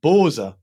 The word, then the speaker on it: Bose: But then Germans pronounce Bose as Borza, or some Germans do.